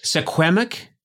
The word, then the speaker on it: Suquamish: Suquamish? – Or that's pretty good. No, that you did really well there.